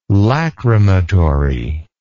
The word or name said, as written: lachrymatory